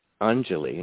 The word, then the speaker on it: Anjali: And there's a Sanskrit word, Anjali, and it's spelled A-N-J-A-L-I.